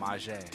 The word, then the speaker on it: Mazhe: You said it wrong. I'm again? – Not the homie. Yeah. Again. – What is it then? – Mazhe. – Mazhe. I keep calling you Mazhe.